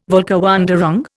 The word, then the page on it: Völkerwanderung: Indian